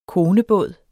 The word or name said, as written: konebåd